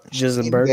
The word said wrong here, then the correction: Gisbergen: Van Gisbergen.